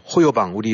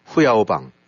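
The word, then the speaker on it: 후야오방 (胡耀邦): 그러다가 이제 불을 지르게 된 것이 어, 호요방 우리 뭐 한자식 발음으로는 호요방이고 예. – 후야오방 어, 그 당시에 이제 총석이 음.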